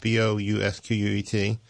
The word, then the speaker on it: Bousquet: Her name is Sharon Bousquet, and it's spelled like bouquet, but with an S in it, Bousquet.